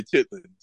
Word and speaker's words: chitterlings: No, Ch- see the, the, you know we, I know chitterlings. I know, We, in the hood we say chitlins.